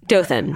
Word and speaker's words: Dothan: Dothan.